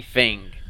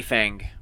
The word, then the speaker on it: Feng: And so the Jeff Bridges character was this guy named Johnny Fing. – Johnny Fing. – Johnny Fang. – Fing. Fing. – F-E-N-G. – Fing.